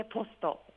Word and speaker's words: POSTO: 王線仙川駅から歩いて 2 分、調布仙川郵便局の目の前にあるポストに来ています。ポストといっても赤い郵便ポストではありません。郵便局の道路を挟んで向かい側にあるクリーム色のとってもおしゃれな建物で、綴りはアルファベット大文字で POSTO と書いてポストです。